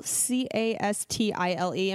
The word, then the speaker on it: Castile: Um, it's it's Pure Castile, C A S T I L E.